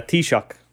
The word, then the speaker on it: Taoiseach: So it's a Taoiseach.